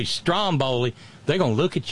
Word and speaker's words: Stromboli: God damn it, it's a Stromboli. The stress is on the first syllable. It's a volcano on an eponymous island off the coast of Italy. Stromboli. Like Strom Thurmond, right?